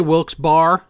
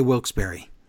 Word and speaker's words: Wilkes-Barre: Some people say Wilkes-Barre. Some people say Wilkes-Barre.